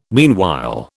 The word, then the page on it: meanwhile: British English